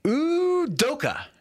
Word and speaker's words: Udoka: My favorite new coach, or at least I like to say his name, for the first time ever, Udoka.